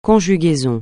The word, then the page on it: conjugaison: francais